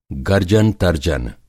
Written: गर्जन-तर्जन

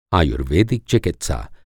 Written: आयुर्वैदिक

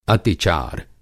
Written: अतिचार

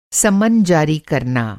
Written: सम्मन

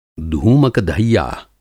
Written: धूमक-धैया

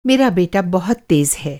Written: तेज़

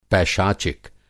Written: पैशाचिक

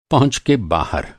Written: पहुँच

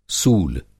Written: सूल

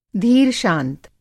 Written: धीर-शांत

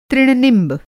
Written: तृण-निंब

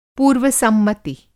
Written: पूर्व-सम्मति

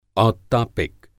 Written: औत्तापिक

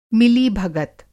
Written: मिलीभगत

